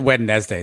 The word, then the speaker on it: Wednesday: Question, why don't we pronounce the D in Wednesday? Why is it Wednesday? Does anybody know? – Asking the hard questions this week.